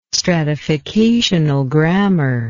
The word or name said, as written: stratificational grammar